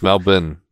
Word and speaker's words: Melbourne: Well, it's Melbourne. I pronounce it with like an IN sound, not a, you know, born. – But, born. Yeah. That's the American way to say it right there.